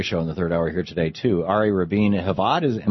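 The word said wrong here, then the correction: Ari Rabin-Havt: Shano tells me it's pronounced Ari Rabin-Hoft.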